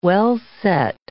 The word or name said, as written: well-set